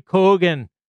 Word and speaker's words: Keoghan: So one of the characters, Barry Keoghan, is that how you pronounce that guy? – I'm terrible Kogan. With Irish names. – All right. Yeah. Barry Keoghan. – There we go.